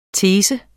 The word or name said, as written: Teses